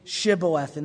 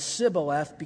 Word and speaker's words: Shibboleth: And they would give them this test, say Shibboleth. And they would say it as Sibboleth because their dialect would not be able to pronounce it properly.